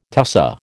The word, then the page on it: tussah: American English